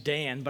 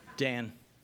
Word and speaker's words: Dan: I mean, my name's Dan, but I thought I'd spell it for you because it's very complex. D-A, the number 12, 9, capital W-N, Dan.